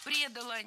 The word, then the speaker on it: предала: Его Галштинская гвардия не предала и готова была за него умирать. – Предала, неверно, правильно, предала. Ударение должно падать опять же на окончание.